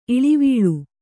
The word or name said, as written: ಇಳಿವೀಳ್